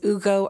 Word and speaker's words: Ugo: As you correctly pronounced, my name is Ugo Arinzeh, and I am founder and managing director of Onyx Property Consultants.